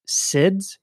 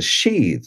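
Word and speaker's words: sídhe: And then it sounds like in the mythology, a lot of that other world contact is happening at these, you call them SIDs? Is that how you pronounce it? – Oh, yeah, S-I-D. It's a sheath. It's called the sheath mound.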